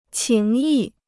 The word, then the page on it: 情谊: qíng yì